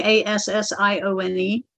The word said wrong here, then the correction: Alcyone: Alcyone. – Ah, okay. Now I've heard of that. – When I hear that star, you know, when I asked how to pronounce, I didn't hear L, so that's how I say it.